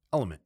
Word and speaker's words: LMNT: This is one last time with one more sponsor of ours, and that is the drink mix LMNT, pronounced element.